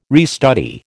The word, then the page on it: restudy: American English